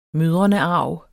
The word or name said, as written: mødrenearv